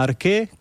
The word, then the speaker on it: ARCHE: Sí, para quienes tengan interés, les recomiendo que visiten la página ARQUE, que se escribe ARCHE,